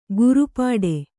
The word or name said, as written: ಗುರುಪಾಡೆ